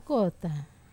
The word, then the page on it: kota: Wamesa